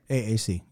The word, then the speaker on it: AAC: However, the thing about it is that with that codec, if you have a file that's encoded ACC, right? – AAC. Yep. – Sorry, I keep saying that incorrectly.